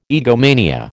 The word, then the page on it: egomania: American English